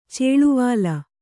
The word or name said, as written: ಚೇಳುವಾಲಿ